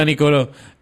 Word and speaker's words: Niccolò: Se dice, se pronuncia bien Nicolò, o sea, con doble C, N-I-C-C-O-L-O, y hay una, un acento en la última O. – Ah, ya. Que tienes que, tienes que ponerla, así que es Nicolò.